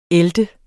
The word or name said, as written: æltet